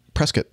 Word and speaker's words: Prescott: heading to Prescott, Arizona. Prescott. Oh, really? Yep. I've never heard anybody pronounce it that way. Interesting. – Local. – Prescott. Yeah. Prescott. Ah, thank you. Like Pier instead of Pierre in South Dakota.